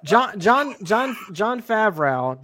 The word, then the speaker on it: Favreau: Look, John Favreau doesn't try to mispronounce his shit. It's spelled exactly how it sounds.